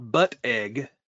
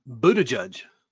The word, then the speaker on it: Buttigieg: I will also mispronounce it, I'm certain, but it's not Buttigieg. I'm fairly certain it's Buttigieg. – Buttigieg. – No, Buttigieg. It has nothing to do with a butt or eggs.